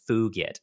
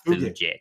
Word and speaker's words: fugit: Well, actually, you see, in Latin, the G is actually hard, and at least in classical Latin, in ecclesiastical Latin, you're allowed to pronounce it like a J, so it's actually tempus fugit, not fugit.